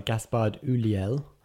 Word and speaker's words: Gaspard Ulliel: He's played by Gaspard Ulliel.